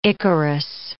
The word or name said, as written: Icarus